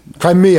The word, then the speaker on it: Crimea: No, C-R-I-M-E-A. – All right. – It's like Ukraine's like... – I think it's actually Crimea. – Crimea. Yeah. That's it. Crimea.